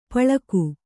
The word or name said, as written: ಪಳಿಗೆ